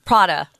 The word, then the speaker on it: Prada: Uh, some of them I w- I didn't even know how to pronounce because they're foreign and uh, but Pravda. Um, oh no, is it Prada? Prada.